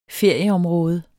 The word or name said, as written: ferieområde